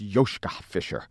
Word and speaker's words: Joschka Fischer: His name is, I'm probably pronouncing it wrong, but I think it's Joschka Fischer. – Yeah, it's Joschka Fischer.